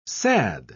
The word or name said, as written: sad